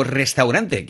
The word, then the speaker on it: restaurant: O restaurante, que en francés sería restaurant, sin la pronunciación de la E, porque ya saben ustedes que si no va acentuada, no se pronuncia, ¿eh? – Claro, exactamente.